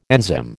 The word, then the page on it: enzyme: American English